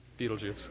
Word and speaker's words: Betelgeuse: There you got Betelgeuse. That really is how it's pronounced, Betelgeuse. It is.